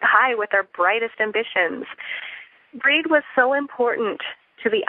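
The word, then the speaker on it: Brigid: Some of you may know her as Bridget, um, but the ancient Gaelic pronunciation is Breed.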